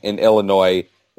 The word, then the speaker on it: Illinois: was him calling you out for pronouncing the S in Illinois, with him actually being from Illinois, and you just kept saying it willfully.